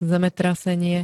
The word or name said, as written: zemetrasenie